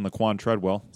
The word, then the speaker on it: Laquon Treadwell: They added a receiver in Laquon Treadwell. Said his name right. Nice. Laquon Treadwell.